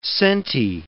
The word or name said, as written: sente